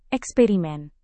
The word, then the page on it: eksperimen: indonesian